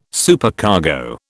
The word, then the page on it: Supercargo: British English